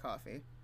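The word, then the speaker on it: Ko-fi: Also, coffee. Is it coffee? – It's pronounced coffee.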